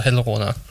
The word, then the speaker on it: Helruna: det var, det var Brainstorm og Helruna. Jeg ved ikke, hvorfor jeg altid udtaler ting med R på den måde, men det er det, de hedder.